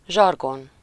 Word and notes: zsargon: /ˈʒɒrɡon/

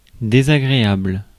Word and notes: disagreeable: [dɪsəˈɡɹiəbəɫ]

